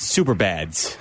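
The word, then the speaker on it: Superbas: The Super Bass or Super Boz? – As the pronounced Super Bads.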